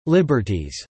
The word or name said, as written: liberties